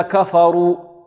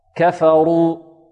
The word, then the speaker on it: كفروا: البعض ربما يفخم الكاف والفاء، يقول: بل الذين كفروا كفروا كفروا ك، خطأ، كفروا. والبعض ربما لا يفخم الراء تفخيما جيدا، يقول هكذا: بل الذين كفروا كفروا رو، لا رو، كفروا.